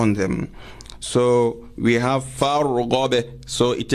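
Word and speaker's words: farghab (فارغب): So for this, we spell it farghab. Then you say fa fatha alif al wasl ra sukun far ghain fatha ba sukun ghab far ghab. I hope the listeners do not forget the letter of qalqala, which is ba.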